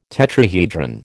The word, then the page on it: tetrahedron: American English